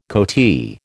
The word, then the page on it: coatee: American English